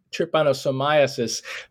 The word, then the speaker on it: trypanosomiasis: I'm going to pronounce it trypanosomiasis.